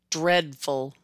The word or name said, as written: Dreadful